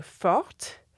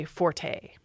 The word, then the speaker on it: forte: The French would say fort, which becomes fort in English, but now very often we say forte.